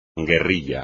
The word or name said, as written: guerrilla